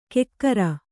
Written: ಕೆಕ್ಕರ

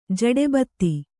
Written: ಜಡೆಬತ್ತಿ

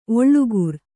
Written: ಓಲೆಕಾರ

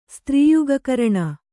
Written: ಸ್ತ್ರೀಯುಗಕರಣ

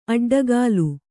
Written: ಅಡ್ಡಗೆಲ್ಲು

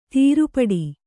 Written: ತಿರುಪಾಟ್ಟು

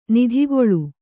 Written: ನಿಧಿಗೊಳು

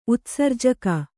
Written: ಉತ್ಸರ್ಜಕ

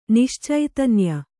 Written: ನಿಶ್ಚೈತನ್ಯ

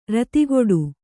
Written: ರತಿಗೆಡು

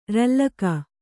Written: ರಲ್ಲಕ

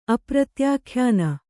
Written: ಅಪ್ರತ್ಯಾಖ್ಯಾನ

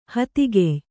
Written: ಹತ್ತಿಕಾಯಿ

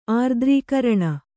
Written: ಆರ್ದ್ರೀಕರಣ

